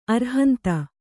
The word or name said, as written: ಅರುಹಂತ